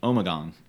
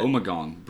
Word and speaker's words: Omegang: Well, from, and you're going to have to correct me if I don't pronounce this right, Omegang? – Yeah, Brewery. Omegang. – You got Omegang Brewery.